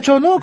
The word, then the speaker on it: Chono: así es porque el señor Chono se pronuncia Chono cómo se pronuncia sí Chono Chono